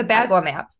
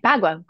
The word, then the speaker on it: Bagua: And at that event, I met another interior designer who recommended you to me because I had no idea about, I'm going to say it wrong, but the Bagu map. Bagu map? Don't ask me. – Yeah, the Bagua map. Mm-hmm. – Okay, Bagua. Great. That's how you say it.